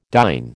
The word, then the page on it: dyn: American English